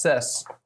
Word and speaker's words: Cess: C-E-S-S. Or it's not Kess. Could be, though. You never know. – No, right. Phonetically, that's Sess.